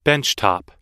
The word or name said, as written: benchtop